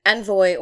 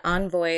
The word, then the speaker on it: envoy: So, first of all, an envoy or an envoy, it's pronounced both ways.